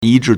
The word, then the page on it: 一致: yīzhì